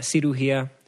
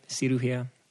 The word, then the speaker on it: cirugía: I say surugía. Cirugía. Cirugía. – Oh, you say it wrong too, and I still got it.